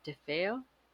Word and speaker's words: DeFeo: It's about Ronald DeFeo Jr. Is that how they say it? DeFeo? – Yes, you are correct.